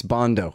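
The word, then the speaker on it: Bandow: Him and Doug Bando, too. I mean, it's Bondo. I always say it wrong.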